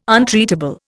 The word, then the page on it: untreatable: Indian